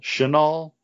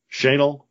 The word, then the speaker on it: Chenal: So they're not playing now, but, as of today, in action at the Little Rock Invitational at the, How would you pronounce C-H-E-N-A-L? Chenal? Chenal? It doesn't matter. It's a country club in Little Rock, Arkansas. – Chenal. – Chenal? Yeah. We're going to call it Chenal.